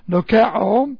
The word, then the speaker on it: dokeo: The Greek word is doxē, and this is doxē, D-O-X-A, doxē. It comes from the root, the verb, dokeo, D-O-K-E, D-O-K-E, long O, dokeo.